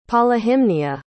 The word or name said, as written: polyhymnia